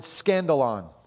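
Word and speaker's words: skandalon: The word stumbling block in the Greek is the word scandalon. Sounds like the word scandal, doesn't it?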